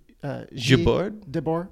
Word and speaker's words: Debord: Guy Debord? – I think it's pronounced G. The French pronunciation is Debord. – Yeah.